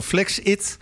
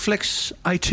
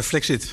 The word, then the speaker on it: FlexIt: Medeoprichter van FlexIt. Zeg ik dat goed? Of is het Flex IT? – Nee, het is FlexIt.